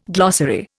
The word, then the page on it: glossary: Indian